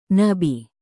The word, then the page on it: nabi: Indonesian